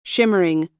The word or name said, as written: shimmering